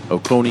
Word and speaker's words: Oconee: Athens Clark County Police later confirmed to The Athletic the investigation was in neighboring Oconee County. I say that right? Oconee. – You gotta ask TC. – O-C-O-N-E-E.